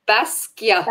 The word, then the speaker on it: Basquiat: How do you say that? Basquiat. – Basquiat. – No. Basquiat. Basquiat. Basquiat. Yep.